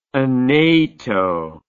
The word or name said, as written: Aneto